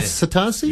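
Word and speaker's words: Asotasi: Is that the correct way to say it? Yeah. Asatasi? – Yeah, you got it right.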